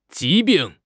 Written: 疾病

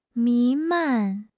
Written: 弥漫

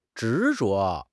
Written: 执着

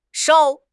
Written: 捎

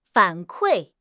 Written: 反馈

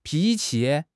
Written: pi2 qi5

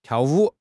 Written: tiao4 wu3

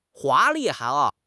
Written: 华丽

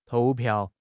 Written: 投票

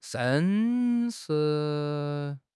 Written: sheng1

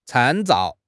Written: 参照

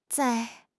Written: zai4

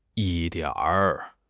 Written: yi1 dian3 r5

